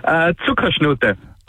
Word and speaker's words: Zuckerschnute: How do you say it? – Zuckerschnute. It's like a German equivalent to like honey.